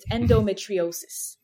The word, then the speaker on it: endometriosis: So I'll pronounce it the American way. It's endometriosis.